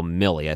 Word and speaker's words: Milley: Yeah, I think that's pronounced General Milley. I said it wrong before.